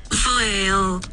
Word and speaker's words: voile: Dia ejaan dia adalah V-O-I-L-E. Ianya adalah perkataan yang berasal dari bahasa Perancis yang bermaksud kain penutup atau ringan. – Jadi ejaan ni V O I L E. – Itu je. Sebutan ni okey, kita dengar sama-sama eh. Ini saya dapatkan daripada ni. Ini adalah sebutan dia. Okey. – Foil.